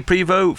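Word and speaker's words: Prévost: Prevost was credited for percussion. – Yeah, Eddie Prevo from AMM. – Sorry, I've pronounced two legendary people's names incorrectly so far in this interview.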